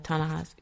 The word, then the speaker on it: Ta-Nehisi Coates: There was a big, and I'm going to screw this up, Ta-Nehisi Coates? – Yeah, uh-huh. – Is that right? – Right, right. – Is that the right pronunciation? – Yeah, Ta-Nehisi, yeah.